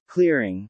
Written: clearing

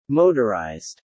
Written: motorized

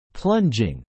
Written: plunging

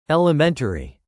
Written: Elementary

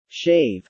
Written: shave

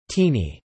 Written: teeny

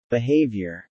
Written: behaviour

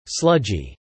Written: sludgy

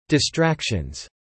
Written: distractions

